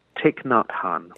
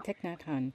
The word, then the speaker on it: Thich Nhat Hanh: I love how someone mentioned, is it pronounced Thich Nhat Hanh, is that correct? – Thich Nhat Hanh. – Thich Nhat Hanh. – Yeah.